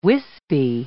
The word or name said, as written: wispy